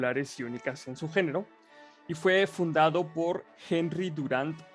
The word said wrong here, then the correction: Dunant: o Henry Durant, la neta, no sé cómo se pronuncia bien, güey. Dunant, perdón, güey.